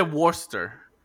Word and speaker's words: Worcester: Se dice Worcester, pero se escribe como que Worcestershire.